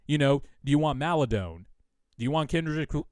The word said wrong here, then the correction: Kenrich: Kendrick uh, Kenrich Williams? Finally, got the name right.